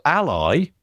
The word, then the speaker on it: Ally: It's a new product, it seems, and it's called Ally. A11Y, you know, but it's spelled Ally, A-L-L-Y.